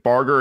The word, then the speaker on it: Barger: I was asking Bill beforehand, you know, do I say his name with a, you know, is it Barger? And he goes, yeah, with a hard G, just like me.